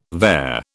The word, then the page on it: vair: British English